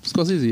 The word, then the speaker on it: Scorsese: Weil ich dachte Scorsese. Scorsese, ja. – Ja, genau. Deswegen sage ich so, weil er in Interviews, wo er dabei ist, akzeptiert er die Aussprache und sagt das glaube ich selber so.